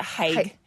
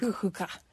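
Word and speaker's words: hygge: People pronounce it haig, but it's actually pronounced like hookah.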